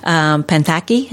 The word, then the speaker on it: Panthaki: Ray Panthaki, is that how you say his name? – Yes, okay. That's right. That's perfect.